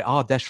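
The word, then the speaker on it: Ardèche: Uh, Christopher Duma um, at Archer Football. I hope I said that right. Archer, no, Ar- sorry, Ardesh Football.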